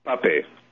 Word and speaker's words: Pape: I had a guest, a very well-known guest, who had recommended your book, and he had pronounced it Pop. I thought that the E would probably come into play there and that it would be Pape.